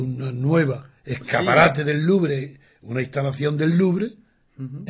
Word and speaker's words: Louvre: Sí, no, yo la mía es mala también, pero con que no pronuncien la E, basta. Louvre. Sin pronunciar la E.